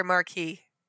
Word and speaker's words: marquess: Is it marquis or marquis? – In French, it's marquis. But in English, it's marquis. M-A-R-Q-U-E-S-S is pronounced marquis.